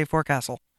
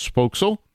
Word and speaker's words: forecastle: The proper pronunciation of the word is foxhole.